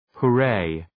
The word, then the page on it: hooray: hʋ’reı